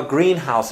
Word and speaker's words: greenhouse: And in the second example I had given, I have a greenhouse in my backyard. As you can see, greenhouse is spelled all together in one word. And then the stress falls in the first part of the word, so greenhouse.